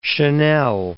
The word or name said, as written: Chanel